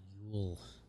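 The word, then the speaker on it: Llull: Yule. That is how it's pronounced.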